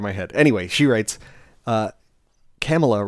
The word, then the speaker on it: Kamala: It is from Izzy, and she writes, Kamala rhymes with Mamala.